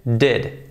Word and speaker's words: did: Cuidado que no es did, vais a decir, ¿cómo está pronunciando? No, no es did, es did, did. Prácticamente una E.